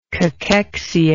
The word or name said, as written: cachexic